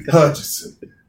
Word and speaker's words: Hutchinson: You got Asa Hutchins. – Yes. – Is it Hutchinson? – Hutchinson. It really doesn't matter.